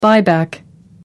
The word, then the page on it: buyback: American English